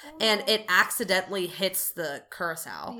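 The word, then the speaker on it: Curacoa: It's being escorted by a smaller ship, the HMS Curacao, I think is how you say it. – Yep, that sounds right.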